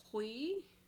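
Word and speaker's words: Huy: Hui? – Yeah. – Why? It's H-U-Y.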